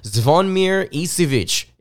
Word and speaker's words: Zvonimir Ivisic: Zvonimir Ivisic is how you pronounce his name, for those of you out there that don't know, a 7'2" center from Croatia.